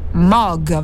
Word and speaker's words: Moog: E invece il nostro professore aveva pronunciato bene, si dice Mog, vero? Infatti questa mh, mm, trae facilmente l'inganno. inganno, eh, ogni volta che noi italiani vediamo due O eh, automaticamente eh, pronunciamo U, è, eh, è, anche normale diciamo, invece no, la pronuncia corretta eh, come del resto appunto eh, dice anche lo stesso, lo stesso MOG, è appunto con, con le due O eh, una O profonda, diciamo, mo, eh,